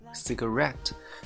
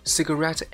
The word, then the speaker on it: cigarette: 注意了，这个单词呢，在本文当中，你刚才听听力的时候啊，可以注意它发音的重音在后面。 cigarette，它是英式发音，然后如果说美式发音呢，它就会发成 cigarette，cigarette，它会在前部发音。这个的话，这个一个小细节哦，就是说这个 cigarette ends，这个 end 它是指末端，就是说香烟的末端，就把它翻译成香烟头，对不对？